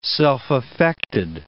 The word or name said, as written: self-affected